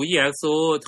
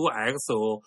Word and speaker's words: EXO: 我那个上期直播也有几，上期那个视频有几个错误，大家就是指出来了。比如说那个 EXO，我读 EXO 韩国那个，其实人家读 XO，那个 E 是不发音的，我也比较土，很多人都指出了。